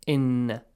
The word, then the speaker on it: إن: حمزة إي has to be pronounced correctly. Do not get it wrong, okay? It's not عين sound. إي, إن. Exactly like the letter A in English, or the letter E, yes, or the letter I.